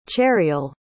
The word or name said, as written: chariel